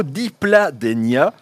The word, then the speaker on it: Dipladenia: j'ai reçu ce beau Dipladenia. Comment dois-je faire pour cet hiver pour le conserver ?» C'est bien prononcé pour le nom de la plante, oui, tout à fait.